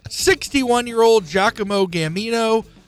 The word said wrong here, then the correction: Gamino: was tracked, to be clear, Gamino, not Gambino.